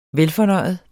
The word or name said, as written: velfornøjet